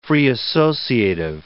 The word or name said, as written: free-associative